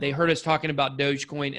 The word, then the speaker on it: Dogecoin: So can you share, what are all these like Ethereum and like Dogecoin? What are these weird names and how can I know what to invest in? – Dogecoin. I love that pronunciation. Yeah. – It's Doge.